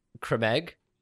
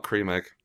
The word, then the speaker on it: Creme Egg: Also, fun fact, it's pronounced creme egg. – Creme egg? – Creme egg, not cream egg.